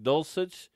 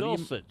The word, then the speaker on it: Dulcich: I don't think that's how his name is pronounced. But Dulcich. – Okay. – What do you think it is? – I don't know. I don't remember.